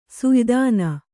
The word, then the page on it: ಸುಯ್ದಾನ: suydāna